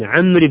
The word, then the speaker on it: عمرو: عمر هذه عمر تكتب بالواو تفريقا لها عن عمر بس الواو ما تنطق يعني ما نقول مثلا عن أبي بكر محمد بن عمرو بن حزم نقول بن عمرو